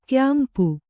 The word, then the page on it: ಕ್ಯಾಂಪು: kyāmpu